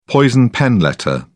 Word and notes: poison pen letter: British English

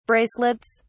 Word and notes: bracelets: British English

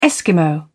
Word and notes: eskimo: British English

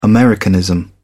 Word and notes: Americanism: British English

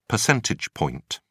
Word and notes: percentage point: British English

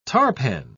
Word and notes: tarpan: British English

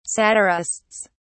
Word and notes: satirists: British English

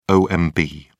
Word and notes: omb: British English

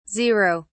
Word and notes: 0: British English

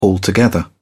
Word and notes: altogether: British English

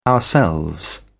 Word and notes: ourselves: British English

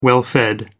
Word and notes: well-fed: American English

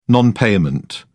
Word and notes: non payment: British English

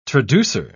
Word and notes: traducer: British English

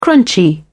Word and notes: crunchy: British English

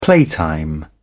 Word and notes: playtime: British English

